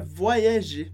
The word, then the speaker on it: voyager: A gente pode imaginar a palavra voyager escrita dessa maneira. Voyager. Como se o Y se dividisse em dois Is. Então, por isso a gente não pronuncia voyager, mas voyager. Voyager.